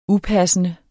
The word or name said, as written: upassende